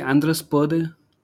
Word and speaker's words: Andrus Purde: Did I pronounce your name correctly, Andrus Purde? – Yeah, this is actually very close to the Estonian pronunciation.